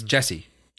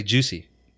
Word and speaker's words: Jussie: His name's actually, it's spelled J-U-S-S-I-E, but it's Jesse. – Yeah. – Jesse. – Jesse. – I would say Juicy.